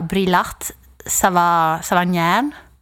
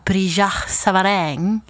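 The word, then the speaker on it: Brillat-Savarin: Jag brukar säga Brillat Savagnin. – Ja. – Men när jag kollar upp det så säger de att man ska säga Brillat Savagnin.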